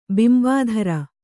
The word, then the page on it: ಬಿಂಬಾಧರೆ: Kannada; bimbādhare